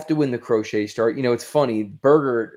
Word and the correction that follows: Berger: or whatever his name is, forgive me for pronouncing it the way I did. – Berger. – Yeah, Berger. I'll say Berger.